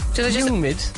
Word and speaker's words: humid: Like the way you'd say John Hume. – Humid. – Lengthen the U.